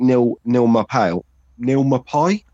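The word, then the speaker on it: Neal Maupay: And I like Neil Mapai, I think you pronounce his name.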